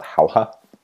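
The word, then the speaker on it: Jauja: It's called Hauha, and that is how you say it.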